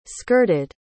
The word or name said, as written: skirted